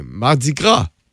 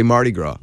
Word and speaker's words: Mardi Gras: Mardi Gras is the real pronunciation, but we say Mardi Gras.